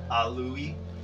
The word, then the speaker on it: Alawy: Yeah, it's not spelled how it sounds. It's Welsh. Or Elven. A-L-A-W-Y.